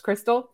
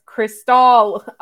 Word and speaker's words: Cristal: So I think for me, Cristal, I keep mispronouncing him as Crystal, just like yours is Matevi. It's Matve. It's Cristal. I keep yelling at myself.